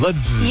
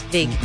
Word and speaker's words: Ludwig: Ludwig. – Yeah, well, in German, a W is pronounced as a V.